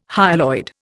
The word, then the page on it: hyaloid: Indian